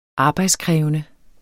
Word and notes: arbejdskrævende: ˈɑːbɑjdsˌkʁεːvənə; ˈɑːbɑjdsˌkʁεˀvənə

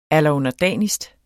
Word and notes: allerunderdanigst: alʌɔnʌˈdæˀnisd; ˈalˀʌɔnʌˈdæˀnisd

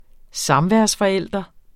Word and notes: samværsforælder: -fʌˌεlˀdʌ; -fʌˌεlˀʌ

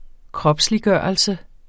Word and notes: kropsliggørelse: ˈkʁʌbsliˌgɶˀʌlsə

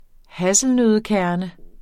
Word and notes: hasselnøddekerne: ˈhasəlnøðə-

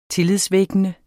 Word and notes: tillidvækkende: ˈteliðˌvεgənə

